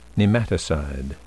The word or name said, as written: nematicide